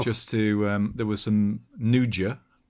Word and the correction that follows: Nduja: is that, am I pronouncing that right, Chris? – Nduja.